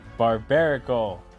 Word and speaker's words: Barbaracle: I did recently build a, how do you say it, Barbaracle? – Barbarnacle. – Like barbarian, like barbarian and barnacle together. Barbaracle.